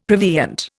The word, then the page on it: prevenient: Indian